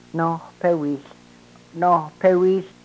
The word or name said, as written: nohpewihl